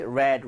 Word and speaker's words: read: Read, red, red.